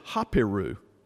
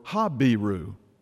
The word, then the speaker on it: Habiru: It's a word in English, we pronounce it Hapiru or Habiru.